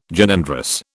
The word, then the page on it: Gynandrous: British English